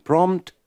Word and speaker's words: prompt: Don't pronounce the second P in prompt and prompter.